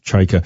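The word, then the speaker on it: Chayka: Apparently we pronounce his name incorrectly. – Chyka. – It's Chyka.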